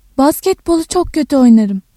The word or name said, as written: oyna-